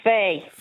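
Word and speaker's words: Faidh: And the other one was Fay. You can spell it F-A-Y if people don't understand it, but it's F-A-I-D-H. – Okay. – G-H, yeah. That might be too. – I pronounce that Fia. – Fia? – Oh, yeah. – Am I pronouncing it wrong? – Yeah, it's actually Fay, yeah.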